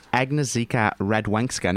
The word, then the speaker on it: Agnieszka Radwańska: Interestingly, tennis star Agnieszka Radwańska. Now, have I said that right? Normally, Rolly, is that correct, Adam? – That is correct.